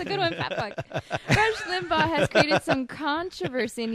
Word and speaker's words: Limbaugh: Republican radio commentator Rush Limbaugh. Am I saying it right? – Rush Limbaugh.